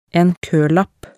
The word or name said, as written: en koelapp